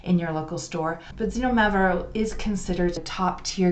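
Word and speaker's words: Xinomavro: And its name is Zinomavro. And Zinomavro is spelt with an X,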